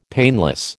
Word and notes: painless: American English

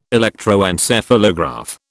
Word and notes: electroencephalograph: British English

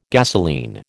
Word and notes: gasolene: American English

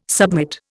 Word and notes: submit: Indian

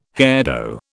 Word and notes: hairdo: British English

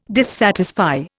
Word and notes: dissatisfy: Indian

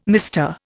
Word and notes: MR: Indian

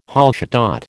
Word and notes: Hallstatt: American English

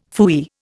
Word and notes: phooey: Indian

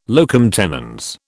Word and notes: locum tenens: British English

